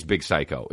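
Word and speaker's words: Big Syke: No, I think it's Big Psycho, even though it's spelled S-Y-K-E, but of course it is because rap.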